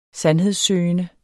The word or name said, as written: sandhedssøgende